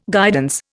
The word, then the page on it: guidance: Indian